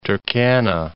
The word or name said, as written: Turkana, Lake